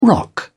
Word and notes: rock: British English

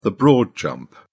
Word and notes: broad jump: British English

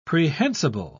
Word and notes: prehensible: British English

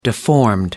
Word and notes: deformed: American English

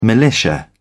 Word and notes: militia: British English

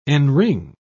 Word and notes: enring: British English

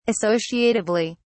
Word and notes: associatively: British English

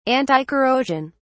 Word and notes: anticorrosion: British English

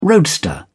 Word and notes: roadster: British English

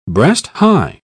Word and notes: breast-high: British English

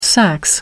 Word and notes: sax: American English

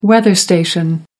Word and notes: weather station: American English

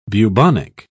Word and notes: bubonic: British English

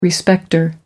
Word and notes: respecter: American English

